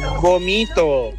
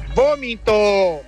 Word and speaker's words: vómito: No se dice vomito. Se dice vómito.